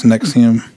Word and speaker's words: NXIVM: NXIVM is spelled, it's NXIVM, but it's pronounced NXIVM.